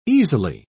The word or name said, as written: easily